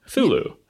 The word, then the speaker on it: Cthulhu: It's like Cthulhu. Thulu. Yeah. You got options, and because the dude who invented the word is dead and gave more than one valid pronunciation before he died, we're going to do what we want.